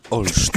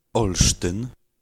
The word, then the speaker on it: Olsztyn: Olsztyn. – Go. – Whoa. Was that you or him? – That was him. – Olsztyn.